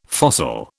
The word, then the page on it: fossil: British English